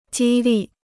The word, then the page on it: 激励: jī lì